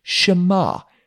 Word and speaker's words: Shema: Let me teach you how to say the Shema in Hebrew. Shema, Israel, Adonai Eloheinu Adonai Echad.